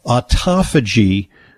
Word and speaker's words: autophagy: And you get extra points for pronouncing autophagy correctly, because a lot of people pronounce it autophagy. It's spelled kind of weird. It's like auto, like a car, phagy. P-H-A-G-Y, and it's a real buzzword in anti-aging medicine these days, because autophagy comes from the Greek word, which means self-devouring.